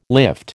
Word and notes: lift: American English

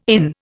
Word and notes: IN: Indian

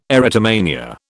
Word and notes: erotomania: British English

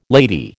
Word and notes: lady: American English

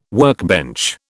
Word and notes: workbench: British English

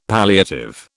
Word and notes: palliative: British English

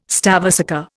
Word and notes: stavesacre: Indian

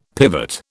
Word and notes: pivot: British English

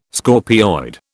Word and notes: scorpioid: British English

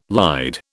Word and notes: lied: British English

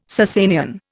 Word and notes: Sassanian: Indian

